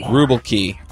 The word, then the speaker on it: Rubelkey: It's like Milwaukee, but with a Russian ruble, so it's Rubelkey. – Rubelkey, awesome.